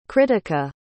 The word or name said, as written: krittika